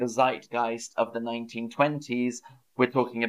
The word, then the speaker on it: zeitgeist: So my next word is zeitgeist. And again, we pronounce it more English than the language it's from.